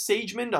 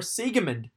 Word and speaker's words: Siegemund: Laura Siegemund, and I thought it was Siegmund. I thought Siegmund, but it's actually Laura Siegemund. That's how you pronounce it, over Paula Badosa 7562.